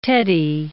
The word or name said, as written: teddy